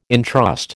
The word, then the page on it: intrust: American English